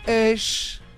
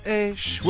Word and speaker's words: eish: Pronounced, or rather spelt first, E-I-S-H. Pronounced A-Y dash. S-H, like shh, like shh. You're shushing a child, or you shh in a library.